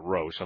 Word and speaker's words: Roche: In Roche's Limit, or Roche's Limit, I pronounce it Roche, I'm sorry, R-O-C-H-E, the all of the asteroid belts and all of the, if you will, the cometary fragments, etc.